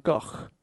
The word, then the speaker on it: Gogh: So his real name is Vincent van Gogh, like a k sound at the end. But then no one says that in English. So more people say Gogh than Go. So I think Gogh is more correct.